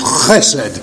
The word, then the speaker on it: chesed: chesed.